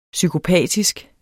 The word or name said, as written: psykopatisk